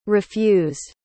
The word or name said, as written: refused